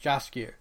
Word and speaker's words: Jaskier: Jaskier.